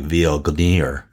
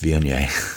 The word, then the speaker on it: Viognier: I was going to be really funny and just call this like Viognier. but it's pronounced Viognier.